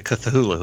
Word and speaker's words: Cthulhu: I figured there's going to be plenty of ads for the Cthulhu Hoop.